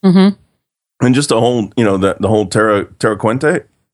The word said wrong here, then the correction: Terra Cruentus: Is that, am I saying it right? – Terra Cruentus. – Cruentus. Okay. Wow. C-R-U. That's weird in my mouth, but anyway.